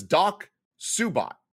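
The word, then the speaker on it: DocSuBOT: How are we supposed to know it's pronounced Doc Subot when you spell it capital D, lowercase O-C-S-U, capital B, capital O, capital T? There's no way in hell you read that Doc Subot.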